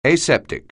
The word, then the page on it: aseptic: anglais